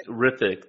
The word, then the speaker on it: Hrithik: Hrithik. The H is silent.